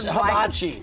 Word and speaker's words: hibachi: Hibachi. Can I say that? – Yes, hibachi.